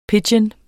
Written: pidgin

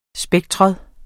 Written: spektrum

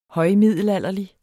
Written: højmiddelalderlig

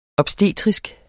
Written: obstetrisk

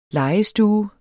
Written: legestue

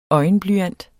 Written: øjenblyant

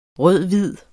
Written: rød-hvid

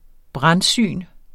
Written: brandsyn

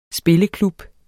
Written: spilleklub

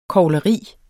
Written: KOGLERIERNE

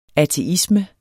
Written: ateisme

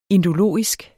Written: indologisk

